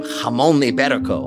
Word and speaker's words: jamón ibérico: Someone in New Zealand found a leg of jamón ibérico on a sidewalk in their neighborhood. Did you like that pronunciation? It's quite legit.